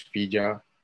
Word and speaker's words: filla: Como por ejemplo, cuando te refieres a tu hija, por ejemplo, la H en el gallego se va, como, entonces, en vez de decir hija, dices filla, o hijo, dices fillo, o como hierro, se pronuncia ferro.